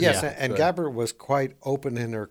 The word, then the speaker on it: Gabbard: Tulsi Gabbard, is that the way you pronounce her last name? – Yes.